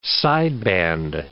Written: sideband

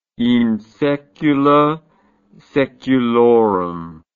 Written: in saecula saeculorum

